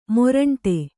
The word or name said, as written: ಮುರುಂಟು